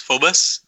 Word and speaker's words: Phobos: But just real quick, the word Phobos, because that's the way it's pronounced, Phobos.